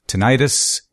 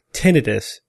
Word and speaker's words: tinnitus: But I prefer tinnitus because tinnitus sounds like inflammation, and that is misleading. Therefore, tinnitus should be preferred.